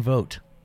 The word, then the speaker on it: Vogt: You can find me at Brendan Vogt.